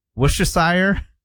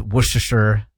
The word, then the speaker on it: Worcestershire: Like W-U-H-S-T-U-H-S-H-R. – Same as like, no kidding, Worcester. – Right, exactly, yeah. Worcester is the county that I'm from in Massachusetts. – Worcestershire. – Worcester, Worcestershire. Worcestershire, S-H-R.